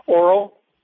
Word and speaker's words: Oro: O-R-O, Oscar, Robert Oscar. – Right. How do you say it? – Oral. – Oral. Oh, okay. – Like an oral exam.